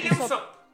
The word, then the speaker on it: Usopp: En el caso de Uspo este, eh, no, sé cómo se dice. Así Usopp.